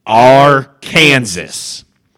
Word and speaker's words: Arkansas: It is pronounced Arkansas.